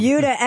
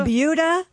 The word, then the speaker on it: Buda: It's called the Buda. – Buda. – Buda. Sounds like Peter Wolf. – It's spelled B-U-D-A, and I looked it up. It is pronounced like beauty.